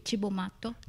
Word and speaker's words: Cibo Matto: Ch- Cibo Matto.